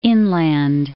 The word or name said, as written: Inland Empire